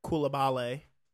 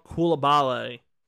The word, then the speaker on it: Koulibaly: Koulibaly. – That's what it is. Yeah, that is how it's pronounced. Bilal Koulibaly was taken number 7 by the Pacers.